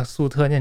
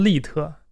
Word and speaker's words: 粟特: 几种新的语言文字，主要有三种啊，一个是粟特语，一个是粟特是粟特人是啊，我今天说的比较多啊，可能啊，粟特人是那个丝绸之路上的商业民族，然后那个那个谁梁文道曾经做一个节目，就讲我翻译那个书，然后他不会念那个粟特，念成利特，嗯，但是这这粟特现在很热啊，什么人都想往粟特这样靠。